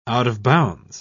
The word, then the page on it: out-of-bounds: British English